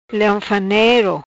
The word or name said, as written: Leongfanero